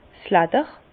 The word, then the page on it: 's Ledder: Bas Rhin; Herrlisheim